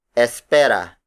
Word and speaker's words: espera: First, espera. Espera. Espera. Espera is spelled as E-S-P-E-R-A.